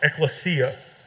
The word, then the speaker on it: ekklesia: Whenever you look at the word church in the Greek, it's a word that's pronounced ekklesia. Now, whenever you break that word down, it's got two parts into it.